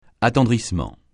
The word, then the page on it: attendrissement: francais